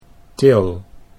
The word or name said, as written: Til